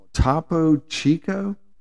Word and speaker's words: Topo Chico: And now you got to top it off with this mineral water called Topo Chico.